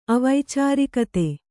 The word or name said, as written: ಅವೈಚಾರಿಕತೆ